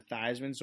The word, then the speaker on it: Theismann: you should change it to Theismann so it rhymes with Heisman, and you get that super cool Theismann for Heisman type crap.